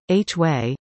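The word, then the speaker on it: Huế: Today we cycle through Hue, pronounced, H-way, and into the vast imperial citadel and the forbidden purple city, one of many, many former imperial capitals in Southeast Asia.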